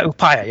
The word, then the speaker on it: Upaya: Yeah, Upaya, yeah. – Upaya, yeah.